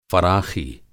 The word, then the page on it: फ़राख़ी: فراخی